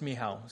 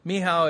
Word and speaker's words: Michał: Now, I know I keep saying his wife, Michael, but it's not actually the right pronunciation uh, it's, it's, uh, so that's lazy on my part. It's uh, That's so that's the way you actually pronounce it, or uh, in Polish it's Michał. So Michał is uh, is is easier to say, but.